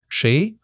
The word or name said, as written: shei2